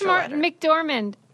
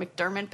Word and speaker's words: McDormand: I like Frances McDormand. – Yes. Frances. And I always call her McDermott, but that's not it. It's McDormand.